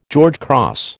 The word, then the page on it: George Cross: American English